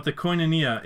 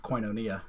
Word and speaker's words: koinonia: When Ford Baptist used to do their once-a-month worship services called koinonia. And the way Lambert explained it to me, like how to pronounce the word, he's like, koin-o, and then tap your knee, like knee-uh.